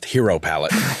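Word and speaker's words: gyropalette: It's called a gyro palette. – Jamie, I think it's actually pronounced hero palette.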